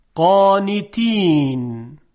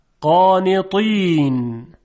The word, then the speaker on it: قانطین: اول با ت دو نقطه. قانتین قانتین. حالا با حرف طا. قانطین قانطین.